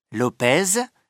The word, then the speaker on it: Lopez: Alami, ça s'écrit A-L-A-M-I. – A. – Moi, c'est Alvaro Lopez. Lopez, ça s'écrit L-O-P-E-Z. – B. Je m'appelle Aya Mendoula. Ça s'écrit M-E-N-D-U-L-A.